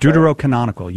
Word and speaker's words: Deuterocanonical: Was the – as you guys call them, Deuterocanonical – I think that's how you say it. – Deuterocanonical, yes. Yes.